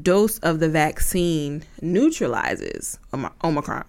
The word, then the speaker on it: Omicron: So it is Omicron, Omicron, Omicron. That is how you say the letter.